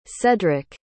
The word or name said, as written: cedric